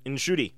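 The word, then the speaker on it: Ncuti: I know that's the way you say his first name, in Shuti. I've been practicing, so I wouldn't completely butcher it. But Nshuti makes a very compelling doctor.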